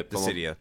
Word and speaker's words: Dissidia: I don't know if you play is it Dissidia, is that how you pronounce it? Is it? I whatever. The yeah the Dissidia. The, Dissidia works.